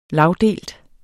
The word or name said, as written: lagdelt